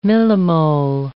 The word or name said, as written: millimole